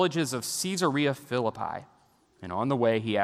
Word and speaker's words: Caesarea Philippi: I want you to notice that Jesus intentionally takes the disciples to Caesarea Philippi. And you can pronounce it that way because it's partly named after Caesar, uh, Caesar Augustus, as well as Philip the Tetrarch.